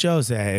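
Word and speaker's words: José: It's Jose, if you're Portuguese, which he is. – Is it? – Yeah. – Oh, okay. I don't know. – They pronounce the J like that.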